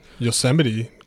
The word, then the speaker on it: Yosemite: Yosemite.